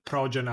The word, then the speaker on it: Projen: By the way, it's spelled Progen as P-R-O-J-E-N. So it sounds like project, but it's Progen instead, which I always find it a little bit of a funny name.